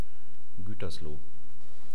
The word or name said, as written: guetersloh